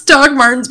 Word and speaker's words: Dr. Martens: Bro, it's Doc Martens, but okay. – Yeah, well, it's spelled D-R, so like doctor. – I know. – But the slang is like Doc Martens. – Yeah. – Or do they just spell it like that, but say Doc Martens? Now I'm confused. – They say Doc Martens and spell it like that, too.